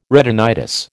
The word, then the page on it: retinitis: American English